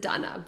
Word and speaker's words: Dana: For the rest of our conversation, we'll pronounce it Donna.